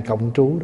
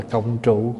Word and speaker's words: cộng trú: Mấy ngày nay mình cộng trú đó. Cộng trú tức là mình sống chung. Có khi đọc là cộng trụ cũng được.